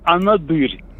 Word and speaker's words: Анадырь: Но правильно, анадырь, естественно. Вы сейчас...